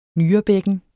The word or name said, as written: nyrebækken